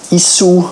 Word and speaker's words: Issuu: Außerdem hat sie eine kleine Software empfohlen, die heißt Issu, I-S-S-U-U. Issu, oder eben ausgesprochen Issu, das erlaubt auf einer digitalen Plattform schöne Broschüren herzustellen, respektive elektronische Broschüren herzustellen.